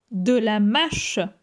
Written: De la mache

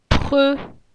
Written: Pre

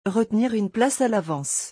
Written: retenir une place à l'avance

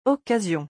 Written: occasion

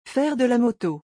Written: Faire de la moto

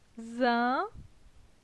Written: Zin

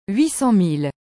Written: 800000

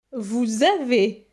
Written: Vous avez

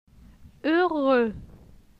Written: Heureux

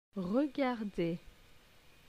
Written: regarder

